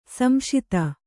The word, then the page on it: ಸಂಶಿತ: samśita